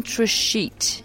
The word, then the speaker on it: Trichet: I mean, he lost in three sets to somebody who a Frenchman called Ben Trichet. – Apparently we're pronouncing that final T, which goes against what we learnt.